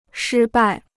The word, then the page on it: 失败: shī bài